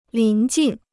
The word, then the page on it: 临近: lín jìn